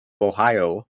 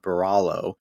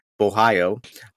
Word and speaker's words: Borralho: And then we got Kyle Bo- Borralho. The, the R's are silent. You guys got to remember that. It's not Baralo, Borralho.